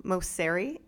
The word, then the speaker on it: Mosseri: And it's crazy because the head of Instagram, he's Adam Mosseri, is how you pronounce it.